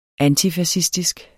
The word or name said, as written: antifascistisk